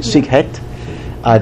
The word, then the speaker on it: Sighet: He is from the town in Transylvania that Elie Wiesel also hails from, Sighet. That's how you pronounce it, Sighet.